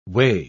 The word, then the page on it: -way: British English